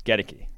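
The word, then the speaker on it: Goedeke: I looked up the pronunciation of my guys. Gedeki, which still doesn't make sense to me.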